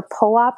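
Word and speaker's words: Phowa: So there's something called the, I believe I'm pronouncing it right, the Powa practice. It's P-H-O-W-A that comes from the Tibetan book of Living and Dying, which is a fantastic book.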